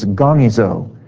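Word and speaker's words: gongizo (γογγύζω): Now, the word in the Greek is gongizo. The first syllable I just pronounced is gong. And it makes me think of the gong show. G-O-N-G.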